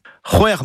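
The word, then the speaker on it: c'hwec'h: Hwer, c'est six, C'H au début et à la fin.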